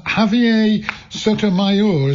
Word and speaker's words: Javier Sotomayor: Um, let's talk about our Latin American uh, sporting legend, um, Javier Sotomayor. Is that about right for the pronunciation? – spot on.